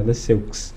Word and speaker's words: Lesseux: É assim mesmo Não, é como se lê mesmo, é Lesseux.